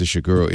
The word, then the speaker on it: Ishiguro: Yeah, I think it's Ishiguro.